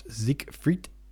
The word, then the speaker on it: Siegfried: And Siegfried, pronounced Siegfried,